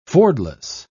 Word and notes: fordless: British English

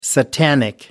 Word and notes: satanic: American English

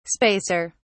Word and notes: spacer: British English

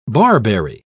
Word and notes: barberry: British English

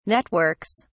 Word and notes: networks: British English

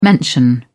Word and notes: mention: British English

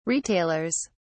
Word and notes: retailers: British English

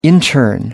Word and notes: intern: American English

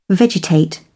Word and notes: vegetate: British English

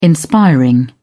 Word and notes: inspiring: British English

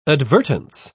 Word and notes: advertence: British English

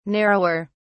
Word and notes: narrower: British English

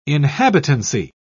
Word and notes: inhabitancy: British English

